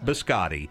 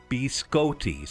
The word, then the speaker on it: biscotti: But Brian says it's Italian and it is pronounced biscotti. Biscotti. Not biscotti, biscotti. So I'm sorry we hurt your ears, Brian.